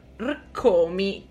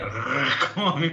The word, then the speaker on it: Rkomi: Io vabbè non riesco a pronunciare il suo nome, perché tu hai detto che si pronuncia con tre R, io avendo la R leggermente moscia mi verrebbe Rkomi praticamente.